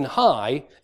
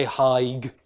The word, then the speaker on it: high: But in hoch, this final consonant is actually pronounced, whereas in high, it's not. You've just got an I diphthong, right? You've got these vowels. You don't say heig or heich or anything like that.